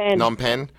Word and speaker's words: Phnom Penh: Phnom Penh.